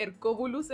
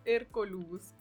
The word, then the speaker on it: Hercolubus: Yo tiendo a decirle Hercobulus en vez de Hercolubus, como que...